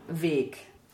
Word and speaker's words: Weg: And you see it, of course, it has a capital letter, capital W, so weg. – And actually, it rhymes with Steg.